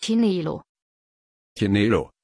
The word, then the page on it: chinelo: German